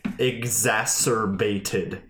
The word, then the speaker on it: exacerbated: I think that only exacerbated. How do you say that word? – Exacerbated. – That word. Exacerbated. – Exasperated is a different word. – Yes, that's like you're tired of it.